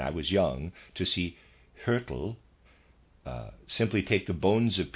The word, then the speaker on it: Hyrtl: Footnote, trying to pronounce the name Hertel, readers aside, spelled H-Y-R-T-L, and I'm pronouncing that Hertel, end of readers aside.